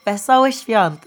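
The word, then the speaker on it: Wesołych Świąt: Oh, Vesołych Świąt. That's how you say it in Polish.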